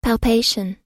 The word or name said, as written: palpation